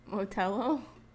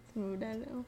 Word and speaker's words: Modelo: Motello? Modelo.